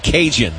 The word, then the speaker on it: Kaegan: Guy from Louisiana named Cajun, but it's spelled K-A-E-G-A-N.